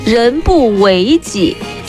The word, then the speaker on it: 人不为己: 但是你们知不知道，人不为己，天诛地灭，这个读音是后面误传的，实际上呢它应该是人不为己，天诛地灭。这个为字啊，它是修为的意思，不是大家现在所认为的为了替啊，为了自己，为了什么什么这样的一个意思。